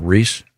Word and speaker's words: Rhys: He pronounces it Reese. – Reese. – Does he? I don't know. – Oh, it is Reese.